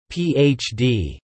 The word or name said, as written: PhD